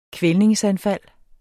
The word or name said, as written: kvælningsanfald